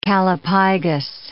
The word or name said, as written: callipygous